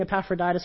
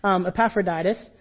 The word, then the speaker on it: Epaphroditus: Informing him of the church and even sending Epaphroditus, who we meet later. Hey, I got the name right. Yes. Epaphroditus, who visits him while he's in prison, and we hear about this, that he's there while Paul is writing this letter.